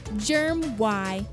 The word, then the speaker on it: Germy: It's spelled Germ Y, like other popular brands, but we prefer to say Germy.